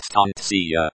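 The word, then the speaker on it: stancia (станция): Stancy. Stancy I, station, singular, feminine form, pronounced stancia. Metro.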